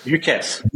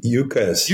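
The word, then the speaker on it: UCaaS: But UCR, is it pronounced UCRs or USARs? – UCAS. – UCAS. – UCAS. Okay. Would never have guessed that.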